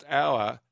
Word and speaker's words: hour: Moira says, our is pronounced our, hower, H-O-U-R.